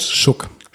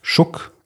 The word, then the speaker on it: Suge: Dezember 1996 zusammen mit seinem Labelchef Such Knight. Schuck. Schuck. Ach, wird der so ausgesprochen? Ja. S-U-G-E und dann als Schuck. Okay oh Gott, Shook Knight.